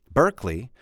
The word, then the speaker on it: Berkeley: Berkeley. Remember the way we pronounce that city? Berkeley. It's spelled Berkeley. In other words, it's spelled capital B-E-R-K-E-L-E-Y. It's pronounced Berkeley.